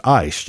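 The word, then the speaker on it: ice (εἰς): It's pronounced ice, just like we would say frozen water. It's actually spelled E-I-S.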